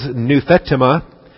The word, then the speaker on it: nouthetema: It does occur in the Septuagint of the Old Testament, and that is nouthetema, N-O-U-T-H-E-T-E-M-A. N-O-U-T-H-E-T-E-M-A. These are both E's. This is the short E, epsilon, and that's the long E, the eta. But it's nouthetema.